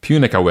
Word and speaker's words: Piunika: Punica Web. – Punica Web. Sorry, that's where... – P-I-U-N-I-K-A.